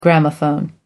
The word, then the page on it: gramophone: American English